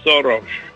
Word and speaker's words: Soros: In Hungarian, we pronounce it Soros.